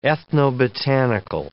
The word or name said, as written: ethnobotanical